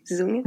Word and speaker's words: Dong: Dong, because you pronounce the D as a Z, so you pronounce her name like Dong.